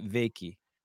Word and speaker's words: Wykie: Wykie. – Wykie. Wykie. You got it. – Wykie, yeah.